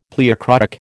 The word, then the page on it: pleochroic: American English